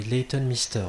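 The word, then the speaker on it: Leighton Meester: Mayton Leston, je crois, un truc comme ça. – Oui, Layton Mister. – Layton Leston, j'arrive jamais à prononcer son nom, c'est trop bizarre.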